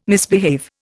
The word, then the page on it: misbehave: Indian